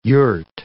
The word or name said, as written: yurt